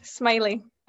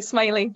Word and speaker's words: Smillie: Yes, correct, Smillie. A couple of my teammates had only recently realised they didn't know how to say it, but it's Smillie, like smiley face.